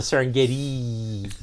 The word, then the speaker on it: Serengeti: No, above the Serengeti. That's, I think, the proper pronunciation there.